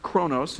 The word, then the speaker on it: chronos: One of the words is pronounced chronos,